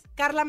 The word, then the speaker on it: Carla: Carla Medina.